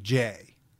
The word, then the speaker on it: Jae: And Joseph really went by the nickname Jay. And it's spelled J-A-E.